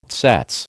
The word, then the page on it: tsetse: American English